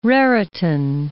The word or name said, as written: Raritan